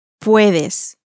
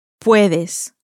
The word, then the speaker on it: puedes: Puedes. Puedes. – Now, this can, this you informal can, is spelt P-U-E-D-E-S.